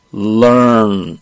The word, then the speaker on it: learn: 윗이빨에 살짝 댔다가 빼는데 중요한 건 중간에 R이 있어요. 중간에 이렇게 R이 있을 때는 네. 약간 굴려줘야 돼. 이게 우리나라 사람들이 런, 런 이렇게 하기 쉬운데 이러면 잘 못 알아듣습니다. 네. 미국 사람들 발음은요. learn 이렇게 한번 살짝 굴려줘요.